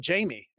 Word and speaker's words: Jamy: And when I, popped out when I was born at the hospital, the nurse asked my dad, and he was like, "Well, Jamy." And she goes, "Well, how would you spell that?" He goes, I don't know. I guess Amy with a J.